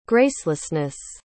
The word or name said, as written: gracelessness